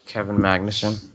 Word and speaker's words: Magnussen: Kevin Magnussen.